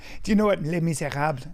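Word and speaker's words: Les Misérables: This guy's doing Les Miserables as he fucking tries. – Les Miserables. Say it right. Les Fuck Miserables.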